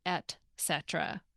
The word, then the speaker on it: et cetera: I thought you were going to ask about the pronunciation, because a lot of people mispronounce the word and will say et cetera when it's actually et cetera, not with the X sound.